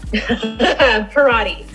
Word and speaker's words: Parati: Parati. – Parati. Okay. Yeah. So I was saying it wrong. – I always tell people it rhymes with karate.